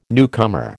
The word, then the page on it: newcomer: American English